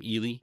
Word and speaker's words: Eli: Is how we pronounce her name, Ely. – Mm-hmm. Spelled like Eli.